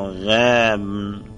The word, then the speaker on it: غبن: کلمه غبن گفتیم غبن، قبن، غبن و قبن و غبن لفظیست ولی از نظر تلفظ و استعمالات متعدده ای دارد. اونی که مورد بحثه به فتح غین و به سکون با غبن که مرحوم شهید همین را تقویت می کند.